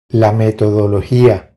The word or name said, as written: La metodologia